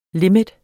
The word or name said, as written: limit